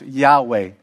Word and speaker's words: Yahweh: And many people believe that it's pronounced Yahweh. But in the original Hebrew, there were no vowels. So that's why I say many people believe, because people not totally sure. But we get the pronunciation of Yahweh because Adonai, which means Lord, has vowels in it, obviously. And so the vowels from Adonai, which means Lord, were placed within YHWH. And so many people believe that the correct pronunciation is Yahweh.